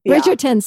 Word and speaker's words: Bridgerton: Did I say that right? – Bridgerton series. Yes.